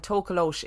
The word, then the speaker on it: Tokoloshe: Just before we go any further, so I looked this up, and Tokoloshe is just the most commonly used pronunciation, but there are lots of other pronunciations of Tokoloshe.